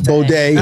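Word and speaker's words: Bode: Is it Baudet or is it pronounced? It's the designer's. B-O-D-E. – Baudet. – I think it's Baudet. – It's uh-huh. Baudet.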